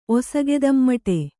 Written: ಒಸಗೆದಮ್ಮಟೆ

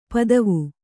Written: ಪದುವ

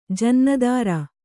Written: ಜನ್ನದಾರ

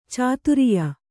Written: ಚಾತುರಿಯ

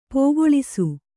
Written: ಪೋಗೊಳಿಸು

